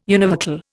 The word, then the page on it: univocal: Indian